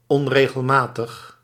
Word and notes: unusual: American English

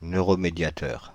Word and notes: neuromédiateur: [nø.ʁɔ.me.dja.tœʁ]

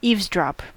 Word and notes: zuhören: /tsuːˈhøːʁən/

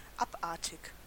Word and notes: abnormal: /ˈæbˌnɔɹ.ml̩/

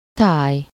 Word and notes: scenery: /ˈsiːnəɹi/

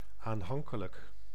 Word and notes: affectueux: France; /a.fɛk.tɥø/

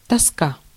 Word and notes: dépression: France; [de.pʁɛ.sjɔ̃]

